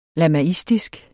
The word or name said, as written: lamaistisk